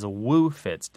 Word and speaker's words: woofits: It is woefits. – Right, sometimes it's spelled and said as woofits, W-O-O-F-I-T-S, and it just means a hangover.